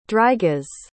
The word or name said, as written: drygas